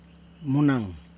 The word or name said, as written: monaN